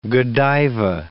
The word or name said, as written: Godiva